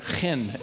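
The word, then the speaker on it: chen (חן): I know that it's not that I have to clear my throat. Chen is how they say that. I'm no Jewish linguist, but chen is how you say that, as I understand it.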